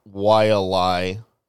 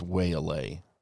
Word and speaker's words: Wailea: I still think it's Waylay. – No, it's not. – That's how it's spelled. – Waylay.